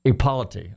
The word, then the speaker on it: Ipolitti: Ipolitti.